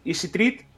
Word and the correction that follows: Easy Thread: Easy T-H-R-E-E-D. – Ah, Easy Thread.